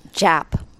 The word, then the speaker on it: Jaap: If I was Scottish, I might say Jaap. – Yeah. – But I'm not, and it's not. It was a name I married into, so okay. – I go by their pronunciation.